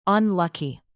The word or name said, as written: unlucky